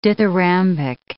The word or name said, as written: dithyrambic